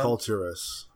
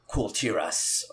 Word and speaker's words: Kul Tiras: specifically sending players to Kul Tiras to fight the Old Gods. Did I pronounce that right? – Kul Tiras. – Kul Tiras. Okay.